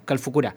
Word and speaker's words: Calfucurá: no sé si se pronuncia como lo decimos siempre, Calfucurá, ¿se dice así? – Sí. – Bueno, Calfucurá,